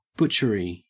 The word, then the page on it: butchery: Australian English